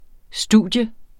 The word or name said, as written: studium